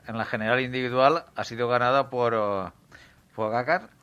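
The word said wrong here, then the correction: Pogačar: eh, Pogachar. – no sé cómo lo ¿Cómo se, pronuncia? Pogachar.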